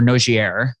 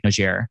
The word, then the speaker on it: Nogier: And this body of work around the Noget frequencies or Nogier, that it's really phonetically, it looks like Nogier, was a French scientist who established different resonant rates associated with different levels of improvement in biological tissue.